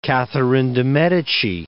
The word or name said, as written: Catherine de Medicis